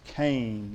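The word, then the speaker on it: Cain (חן): It's spelled C-H-E-N. It's a Hebrew word pronounced Cain, like C-A-I-N.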